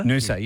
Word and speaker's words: Nusa: I'm flying to Bali in a couple of weeks and travelling with Noosa, N-U-S-A. Is that how you pronounce it? Noosa? – Noosa, yeah, like Noosa Dua, whatever.